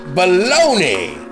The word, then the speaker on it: bologna: Well, hey, in this country, uh, in the U.S. of America, A, that is pronounced baloney.